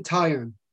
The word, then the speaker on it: Taillon: Sorry if I'm saying that incorrectly, by the way. It's Jameson Taillon. Taillon, thank you.